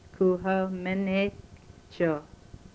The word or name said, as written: ku ho menechoh